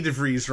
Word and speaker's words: DeVries: Of course, Vicky DeVries rhymes with cheese, so that was really cool.